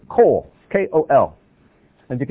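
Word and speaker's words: kol (קול): This is kof, that's kaf. But it's still pronounced kol.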